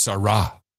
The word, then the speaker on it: Sarah: I was reminded by our resident Catholic, Void Zero, it is pronounced Sarah. Sarah. Not Sarah.